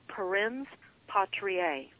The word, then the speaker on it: parens patriae: It's called parens patriae. I'm going to spell it. P as in Paul. A-R-E-N-S, parens, patriae, P as in Paul, A-T-R-I-A-E.